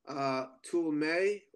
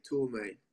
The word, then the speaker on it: Tuleme: Actually, it's pronounced Tuleme, like a toolbox, and the month of May, Tuleme.